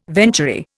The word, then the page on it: venturi: Indian